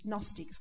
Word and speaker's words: Gnostics: Father, you talked about Arianism and Gnostics.